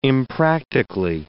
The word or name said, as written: impractically